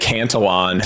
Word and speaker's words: Cantillon: First and foremost, is it Cantillon effect or is it? – I pronounce it Cantillon.